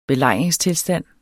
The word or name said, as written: belejringstilstand